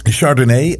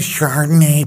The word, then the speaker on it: Chardonnay: dat is een Chardonnay uit eh, Chardonnay. Het is zo erg hoe Amerikanen dat altijd uitspreken. Can I have a Chardonnay, please?